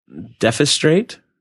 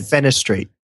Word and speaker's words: defenestrate: Defenstrate. – Okay, I said defenstrate. – Okay. – It's how do you say it? – I gotta look it up phonetically. – Defenestrate.